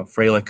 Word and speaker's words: Frelick: First of all, we have Sal Frelick, and I know it's pronounced Frelick because RJ did me the service of making sure we pronounced his name right, against Colton Cowser.